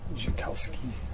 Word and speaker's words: Dyczkowski: D-Y-C-Z-K-O-W-S-K-I. Joukowsky.